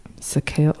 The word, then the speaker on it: Soquel: S-O-Q-U-E-L. Sequel.